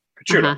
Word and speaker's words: Picciuto: Pitch a baseball, the letter U and the word toe. Picciuto.